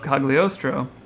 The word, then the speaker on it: Cagliostro: And so they took the animation from a 1979 Lupin film called The Castle of Cagliostro. – Wow, and, that's a great pronunciation.